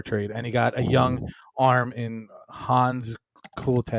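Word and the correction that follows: Hans Crouse: I can't even say his name. – Hans Kraus. – So, yeah, I didn't butcher that name.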